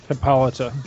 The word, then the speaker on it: Hippolyta: Is it Hippolyta or Hippolyta? How's it pronounced? Do you guys know? – Hippolyta. – Hippolyta. Thank you.